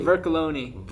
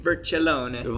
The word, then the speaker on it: Vercellone: Vercellone. – Vercellone. – And it's Italian, so if you want the Italian accent, Vercellone.